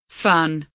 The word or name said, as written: fun